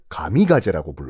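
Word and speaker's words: 가미가제라고 (神風): 그거를 자기네 나라 발음으로 뭐라고 하냐 그 신풍을 가미가제라고 불러요.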